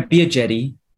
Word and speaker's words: Biagetti: Yeah, Biagetti.